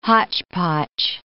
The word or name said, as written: hotchpotch